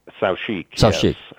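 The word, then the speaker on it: Saoutchik: Now, am I pronouncing this right? Sauché? Well, that was a French coach builder as well, wasn't it? – Sauchique. Yes.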